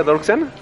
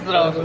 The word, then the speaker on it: Dross: y bueno, el punto es que invitaron a Dross, Drox, ¿cómo se pronuncia ¿Dorksen? – ¿Dorksen? Es Drox.